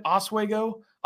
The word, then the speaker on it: Oswego: Dennis, great player from, what is he from, Oswego, is that how you say it, Oswego, there you go, one of the three.